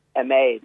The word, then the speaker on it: Emad: No, unfortunately, you're pronouncing it wrong. It's Emad. It's Irish for Emmet.